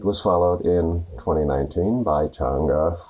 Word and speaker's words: Chang'e: Named after the Chinese moon goddess Chang'e, pronounced Chang'e, the program's first two missions orbited the moon to photograph and map its surface.